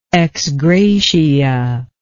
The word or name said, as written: ex gratia